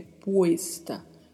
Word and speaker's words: puesta: Tenha bastante atenção ao pronunciar, porque o E é sempre mais fechado, né? Como a gente sempre fala nos episódios do podcast. Puesta, não é puesta, é puesta.